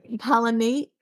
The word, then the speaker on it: Palatinate: Palatinate. – It's a pale taint. – Palatinate. – Bell snickel comes from a pale taint. – Palatinate comes from a bullet taint.